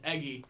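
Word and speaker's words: Eguy: It's spelled E-Guy, but it's pronounced Eggy.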